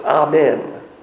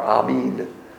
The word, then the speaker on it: amen: If you want to get fancy and pronounce the Hebrew word, it's amen or amin.